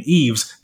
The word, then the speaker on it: Yves: So even whenever we started this conversation, right, you pronounce my name Yves, the S is silent.